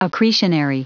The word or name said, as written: accretionary